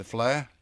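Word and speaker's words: Fleadh: And Belfast just had the flare. Have I pronounced that correctly? – The flare kill, yeah.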